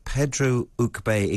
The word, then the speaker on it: Pedro Uc Be: Now, we want to talk to you about Mexico here because you spent time with Pedro Uc Be. Excuse my pronunciation. Maybe you'll. – No, it's good. Is it's that good. okay? – He's yeah, it's a, very good.